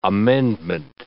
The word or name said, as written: amendment